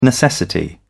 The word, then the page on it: necessity: British English